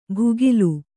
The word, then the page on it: ಭುಗಿಲು: bhugilu